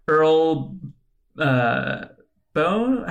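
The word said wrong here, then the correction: Boen: How did we pronounce that? The actor for Dr. Earl Bowen? – Bowen. Earl Bowen, we get the little archival footage from Terminator 2 Judgment Day.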